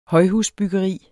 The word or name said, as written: højhusbyggeri